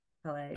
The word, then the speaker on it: Peleg: uh the second flood was like Peleg's flood. Is that how you say it? P E L E G. Peleg. – Peleg. Peleg. Yeah.